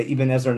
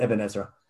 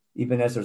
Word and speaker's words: Ibn Ezra: I should say Ibn Ezra, not Ibn Ezra. Okay. Ibn Ezra is 100% correct. I'm just grew up in America, so. I'm 100% right, and I just have an old habit of pronouncing it the wrong way.